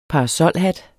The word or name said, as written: parasolhat